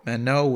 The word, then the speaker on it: Manon: Yeah, M-A-N-O-N, right? – It's M-A-N-O-N. – Yeah. Well, I don't know. That's how you pronounce it. – Oh, French. I thought the last N was silent. – It is. Manon.